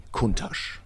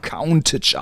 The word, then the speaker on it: Countach: Supersportwagen fängt ja auch irgendwo an, sag ich mal, ab Porsche Turbo, die sehr schnellen Fahrzeuge, aber Lamborghini Countach.